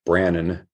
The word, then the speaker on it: Branon: You can also enter my name, which is Mike Brannon, B-R-A-N-O-N.